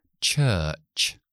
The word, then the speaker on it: church: O a lo mejor quiero decir iglesia, esa palabra, que es CH a principio, CH al final y entre medio schwa larga. Church, church.